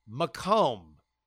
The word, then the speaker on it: Macomb: And then it hit me what I had pronounced as Makem. I guess it was like Macon, Georgia for me, is indeed pronounced Macomb. Said much like Macomb, thanks to the inundation of the French in the early days of colonization.